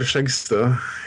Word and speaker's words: Skjegstø: Ansgar Skjegstø is how you pronounce that, his name. Again, Norwegian last names are a mess of consonants usually, and pretty hard to pronounce at times.